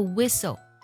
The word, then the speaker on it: whistle: 在这个单词当中，其实有两个字母都不发音，比如说第一个 h 是不发音的，通常我们记的时候呢，可能会记成 WH 组合发 W 的音，但本身来去讲呢 H 是不发音的。除此之外呢，字母 T 也不发音，它读作 whistle 不读作 whistle。